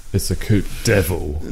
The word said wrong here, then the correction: Coupe de Ville: It's pronounced Deville, mate.